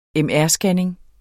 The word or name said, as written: MR-scanning